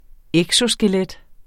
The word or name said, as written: eksoskelet